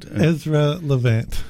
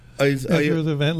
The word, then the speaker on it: Ezra Levant: Ezra Levant? – Ezra Levant. – Well, Ezra Levant.